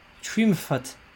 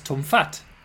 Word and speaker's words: Twmffat: Trümfat. – It's Tumfat. – Oh, I feel like I put the emphasis on the wrong end there.